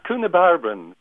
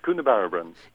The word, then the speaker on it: Coonabarabran: It's Coonabarabran, Barry. It's phonetically spelt Coonabarabran.